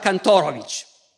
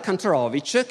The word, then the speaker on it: Kantorowicz: Cambia perfino la pronuncia del nome perché in Germania pronunciava Kantorovic, che per i tedeschi suona meglio. In America comincia a pronunciare Kantorovich, che invece funziona meglio per gli americani.